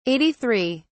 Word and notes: 83: British English

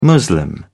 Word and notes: muslim: British English

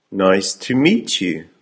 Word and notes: nice to meet you: American English

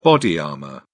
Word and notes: body armour: British English

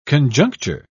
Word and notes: conjuncture: British English

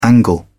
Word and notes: angle: British English

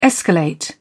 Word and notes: escalate: British English

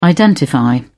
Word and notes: identify: British English

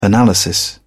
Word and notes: analysis: British English

